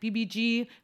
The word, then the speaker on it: VIVIZ: On February 9th, we had a debut of a group called BBG, or VVs, because that's how it's spelled, V-I-V-I-Z. But it's pronounced more like BBG, which is short for Vivid Days, with a Z.